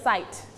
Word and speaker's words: size: คำว่า size ตัวนี้ออกเสียงตัว t เพิ่มขึ้นมานิดหนึ่ง medium size แบบนี้นะค